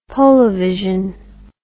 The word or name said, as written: polavision